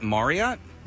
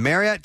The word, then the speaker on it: Marriott: And the Marriott team says that if you're pronouncing Marriott correctly, it should actually rhyme with chariot.